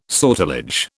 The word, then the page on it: sortilege: British English